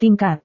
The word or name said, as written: tingkat